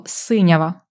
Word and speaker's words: синява: Синява або синява, правильний наголос таки – синява. Це щось синє.